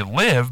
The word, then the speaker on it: live: How do you pronounce the word L-I-V-E? – Well, it's because it's most people would say live, but it's also live.